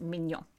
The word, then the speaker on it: mignon: Yes, but it's spelled differently, and it's pronounced mignon.